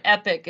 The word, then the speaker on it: Epoch: So it looks like you can pronounce it either way, but Epic is the more common pronunciation.